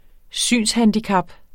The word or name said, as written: SYNSHANDICAP